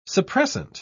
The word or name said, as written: suppressant